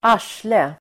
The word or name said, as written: arsle